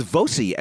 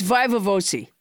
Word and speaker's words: viva voce: But you came up with Voce as your pronunciation. – It's Viva Voce.